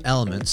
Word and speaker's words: LMNT: I ordered some Element, spelled with the four letters L-M-N-T, which has essentials like magnesium, potassium, and sodium, and over a 30-day period, I saw my headaches decrease by about half.